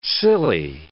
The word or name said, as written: silly